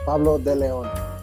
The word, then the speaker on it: Pablo De Leon: Pablo De Leon.